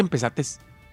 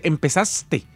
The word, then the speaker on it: empezaste: No se dice empezates. Se dice empezaste.